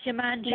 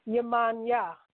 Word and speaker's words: Yemanja: It's pronounced Yemanja. – No. Yemanja. – Okay, Yemanja or Yemanja. Either or is a proper pronunciation.